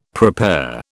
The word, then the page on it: prepare: British English